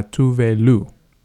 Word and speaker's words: Tove Lo: And this next song is Habits, Stay High, the Hippie Sabotage remix by Tove Lo. Yeah, that's how you say her name in Swedish, because she's Swedish.